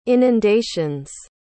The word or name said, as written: inundations